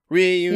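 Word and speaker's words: Riunite: Reunite. You said it right.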